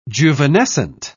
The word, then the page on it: juvenescent: British English